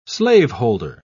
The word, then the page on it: slaveholder: British English